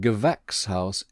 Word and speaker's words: Gewächshaus: And Gewächshaus is like vex.